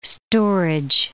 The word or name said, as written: storage